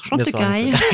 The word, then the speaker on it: Chantecaille: Und seit einigen Jahren ist Beiersdorf zudem dazu übergegangen, externe Marken zuzukaufen, zum Beispiel die Naturkosmetikserie Chantecaille. Ich hoffe, ich habe es richtig ausgesprochen. – Ja, wunderbar, Chantecaille.